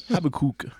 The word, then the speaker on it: Habakkuk: And then I had another colleague who I taught with once that called it Habakkuk. He would really emphasize the kook part. Habakkuk.